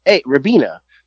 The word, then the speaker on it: Ribena: And the amount of people that are bent that I called it, I said it how it's spelled, R-I-B-E-N-A, Ribena. They're like, no, it's Ribena. I don't see a Y there, so I don't know where they're getting the Y from.